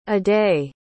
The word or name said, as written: aday